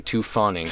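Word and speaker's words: Tu Fawning: It's spelled T-U, actually. Two Fawning.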